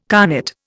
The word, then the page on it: carnet: Indian